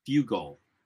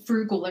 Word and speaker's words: Fugle: Brandon Fugle. – Fugle, yeah. – I keep reading as Frugal every time his name comes up. – I know, as the R is silent.